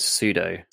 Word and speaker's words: pseudo: And I feel like it would be better if it was more Suedo, if they want to keep that. And is that how you say it? Suedo? Suedo? – I've always said pseudo.